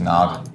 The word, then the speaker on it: GNOG: G-N-O-G. – Nog? I think it's just Nog. – Nog? – Yeah, it'd just be Nog. When you put the G in front of it, it's like gnocchi.